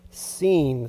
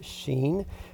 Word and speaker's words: Sin: The whole Israelite community set out from Elim and came to the desert of Sin. So it looks like sin, and it's nothing to do with sinful actions. It's just spelled the same way. The desert of Sin, sometimes even pronounced Sheen, which is between Elim and Sinai, on the 15th day of the second month after they had come out of Egypt.